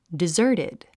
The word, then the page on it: deserted: dizə́ː r tid; ディザァテド